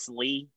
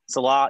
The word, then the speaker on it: Salah: Salee? – Salah.